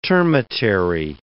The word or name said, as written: termitary